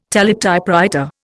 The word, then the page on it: teletypewriter: Indian